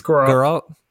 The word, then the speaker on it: Geralt: It's Geralt.